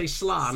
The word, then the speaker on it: slán: I just want to pick you up in your pronunciation of slán, which is the Irish for goodbye. – Oh, man. What did I say? Slán? – Slán, yeah.